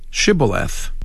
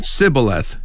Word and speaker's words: Shibboleth: But said Sibboleth instead. – 그것을 Shibboleth라고 발음을 해야 되는데 Sibboleth 했다는 것이지요.